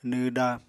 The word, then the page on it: nw da: Apatani